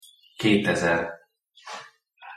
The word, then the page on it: ketezer: Hungarian